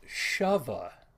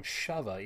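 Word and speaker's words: Sheva: Shava. That's her name, Shava, even though it's spelled out like Shiva, but they all call her Shava.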